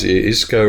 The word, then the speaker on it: Isco: Isco rhymes with disco.